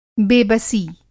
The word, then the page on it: बेबसी: بے بسی